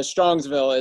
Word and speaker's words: Strongsville: So, some Cleveland Browns news is that Odell Beckham was in Strongville, if I said that right. Is it Strongville? Doing his camp? – Yeah, Strongsville.